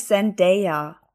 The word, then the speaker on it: Zendaya: Sehr ganz ehrlich, aber sie heißt, glaube ich, Zendaya ich habe mal irgend so ein Question-Ding gesehen, wo sie sagt, wo sie gefragt wird, wie ihr Name ausgesprochen wird. Und sie war so Zen, Day wie Tag.